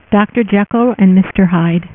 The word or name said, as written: Mister Hyde